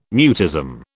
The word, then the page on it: mutism: British English